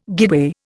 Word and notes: guideway: Indian

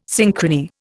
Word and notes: synchrony: Indian

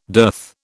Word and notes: dearth: British English

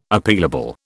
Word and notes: Appealable: British English

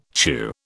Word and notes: chew: British English

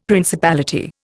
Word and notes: principality: Indian

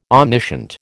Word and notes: omniscient: American English

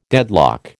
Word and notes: deadlock: American English

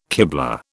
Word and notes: kiblah: British English

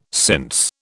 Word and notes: since: British English

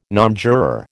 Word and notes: nonjuror: American English

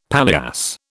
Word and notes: paillasse: British English

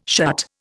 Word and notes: shut: Indian

